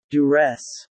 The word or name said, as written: duress